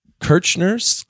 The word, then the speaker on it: Kirchner: So, Jay Kirchner, K-I-R-C-H-N-E-R,